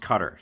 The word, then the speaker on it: Qatar: Cutter is pronounced cutter. See, I said it right this time. The country that is spelled Q-A-T-A-R is pronounced cutter. You know, I researched this a little bit, and I don't think it's wrong to say Qatar. I think that both are used.